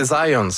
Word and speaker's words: Zajonc: Non, c'est Zajonc. – Oui, OK. – J'ai-tu pas dit Zajonc? – Vous avez dit plus Zajonc, mais. – Je l'ai prononcé un petit peu à l'anglais. J'ai prononcé mon polonais à l'anglais.